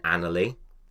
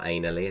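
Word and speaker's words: Anerley: Anyway, James Busby actually died in England in 1871 in Annerley. Pronounced properly, not anally as it's spelt,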